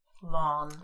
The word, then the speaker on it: Lon: Lon. – Lon. – How do you spell that? – It's short for Laurent. – Lon. – But it's Lon. L-O-N, is Lon.